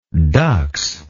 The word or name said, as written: dux